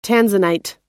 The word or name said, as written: tanzanite